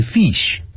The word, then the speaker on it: fish: Fish, 不要念成 fish, 啊 ,fish, 更不要念成呢漳州或者是呢这个厦门啊泉州还有台湾，他们都会念成什么东西呢，会念成会洗 There is one 会洗 in the river. There are two 会洗 many 会洗那就不行了这样啊。我以前小的时候就是念成会喜呀，所以啊要念成 fish，